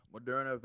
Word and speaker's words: Moderna: But you just said something that piqued my interest about, you said, was it Moderna? I don't know how to say it. – Yes. It was Moderna.